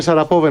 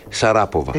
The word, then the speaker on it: Σαραπόβα: Η Μαρία Σαραπόβα είναι αυτή που σας έλεγα προηγουμένως, στην Ίστρια, που δεν είχε φίλες. – Γιατί Πόβα και όχι Σαράποβα? – Τι να κάνω, έτσι την προφέρουν εκεί πέρα.